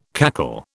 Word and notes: cackle: British English

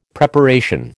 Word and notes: Preparation: American English

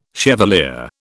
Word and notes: chevalier: British English

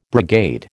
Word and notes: brigade: American English

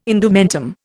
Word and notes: indumentum: Indian

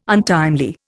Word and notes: untimely: Indian

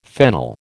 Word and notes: fennel: American English

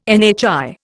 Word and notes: NHI: Indian

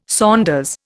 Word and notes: saunders: Indian

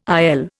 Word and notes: IL: Indian

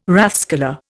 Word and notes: rathskeller: Indian

